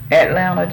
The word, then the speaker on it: Atlanta: That's Atlanta, Georgia.